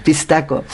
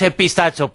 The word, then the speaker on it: pistaco: Las que se cuentan de otro de estos críptidos, de el pistaco, no sé si lo he dicho bien. – Sí, muy bien, sí, pistaco. – Pues es, mira, que, sobre todo, es que lo primero que piensa uno en decir es el pistacho, pero no es eso, ¿no? – No, pistacho no es.